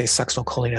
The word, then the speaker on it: succinylcholine: I didn't want to say succinylcholine a fourth time because I was probably going to say it wrong eventually.